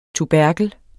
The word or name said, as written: tuberklen